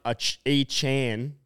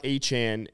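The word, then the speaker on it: Achane: Four, Devon Achan.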